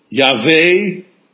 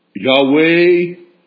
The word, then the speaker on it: Yahweh: T-O-N. They would not profane that name with human pronunciation, so they used this four-letter form and pronounced it Yahweh, Yahweh.